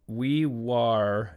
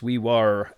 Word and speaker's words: Wee Waa: as he tells me to pronounce it but it's it's written Wee Waa in New South Wales, Australia. Will writes, I live in a remote outback town in New South Wales, Australia named Wee Waa, pronounced Wee Wah.